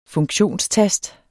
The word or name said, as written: funktionstast